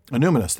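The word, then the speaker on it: Annúminas: I always get the stress on the wrong syllable on this word. – Anumnus? – Anumnus. That's what I thought.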